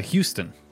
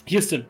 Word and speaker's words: Houston: And then we got Roger Evans, played by Mark Houston or Huston. I never know how to pronounce that last name. – Yeah, Houston. – Houston, thank you.